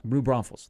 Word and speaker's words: New Braunfels: I butcher the last name. Bromfalis. – Oh, New Braunfels. – New Braunfels. Thank you.